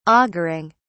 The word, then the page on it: auguring: British English